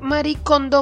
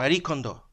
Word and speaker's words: Marie Kondo: Arroba Rosaura Rojas dice, cito, mi mayor maricondo moment es haber emigrado porque no tengo nada. – ¿Cómo se pronunciará eso? Maricondo. Maricondo. Marie Kondo.